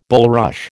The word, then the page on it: bulrush: American English